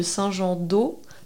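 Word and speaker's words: Saint-Jean-d'Aulps: Un autre petit exemple aussi euh, d'une commune, alors là on n'est ni sur un X ni sur un Z, c'est la commune de Saint-Jean-d'Eau, qui effectivement euh, a U-L-P-S qui se prononce O.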